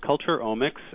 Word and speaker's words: culturomics: The word is culturomics, and it's pronounced culturomics, spelled C-U-L-T-U-R-O-M-I-C-S. It's that O-M-I-C-S ending that confused a lot of people. Some people thought this word should be pronounced culturomics because it seems like it might have to do with economics.